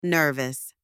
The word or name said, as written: nervous